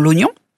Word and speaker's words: oignon: Bref, certains de ces « i » devant « g » et « n » sont restés en place, comme celui de l'oignon, celui de montagne et celui de l'araignée. Et parfois, ils ne se prononcent pas, comme dans l'oignon.